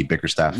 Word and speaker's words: Bickerstaff: That's Bickerstaff? Is it Bickerstaff? – Yeah, Bickerstaff. Yeah, because he used to be an assistant on the Rockets, so I'm familiar with him, Jamie Bickerstaff.